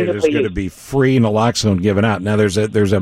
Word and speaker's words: naloxone: And naloxone, is it naloxone? Am I pronouncing that right? – Yeah, so it's interchangeable. It's naloxone, short Narcan.